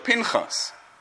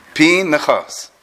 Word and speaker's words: Pinchas: Rosh Shimshon Falhesh says that the name Pinchas, for those who like dikduk here, it's not Pinchas, right? It's Pinchas, right? Because after a chirik and followed by a yud, the shvo is always pronounced.